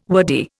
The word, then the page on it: wordy: Indian